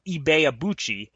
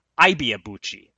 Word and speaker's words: Ibeabuchi: train himself to say Ibeabuchi on air instead of repeating the incorrect pronunciation that most people were using, Ibeabuchi.